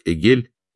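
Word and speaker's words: эгель (עגל): Слово «теленок» на иврите произносится как «эгель» и созвучно английскому «игл» — «дарел», подобно тому.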